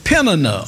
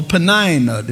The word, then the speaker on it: Peninnah: And one of them name was Hannah, and the other one name was Peninnah or Penina, depending on where you're from. You know, down here in the South, we say her name was Penina. Amen. But you might say Peninnah up north, south, east, or west.